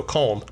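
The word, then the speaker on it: Kalm: But Holm, H-O-L-M? – No, Kolm. – C-O-L-M? – C-A-L-M. K-A-L-M, you got me screwed up.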